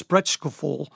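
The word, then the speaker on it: Sprachgefühl: The word she gives for that, there's a German word that more or less is pronounced Sprechgefühl, and it means an intuitive feeling for language, an intuitive love for language.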